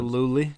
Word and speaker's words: Gillooly: Gillooly.